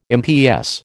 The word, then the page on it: MPS: American English